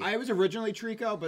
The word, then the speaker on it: Treecko: What's the correct pronunciation? – Treecko. – Okay. – He confirmed it the other day.